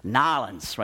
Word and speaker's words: New Orleans: New Orleans, it's like this, Nolens, right? That's how you're really supposed to say it.